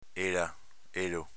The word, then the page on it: ela: Veneto